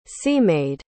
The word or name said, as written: sea-maid